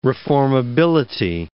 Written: reformability

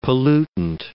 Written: pollutant

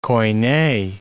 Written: koine

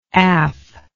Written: aff

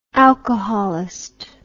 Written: alcoholist

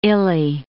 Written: illy

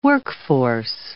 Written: workforce

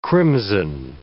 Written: crimson

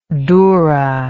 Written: doura

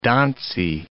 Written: donsy

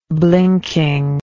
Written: blinking

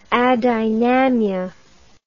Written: adynamia

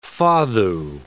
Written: fado

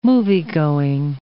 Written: moviegoing

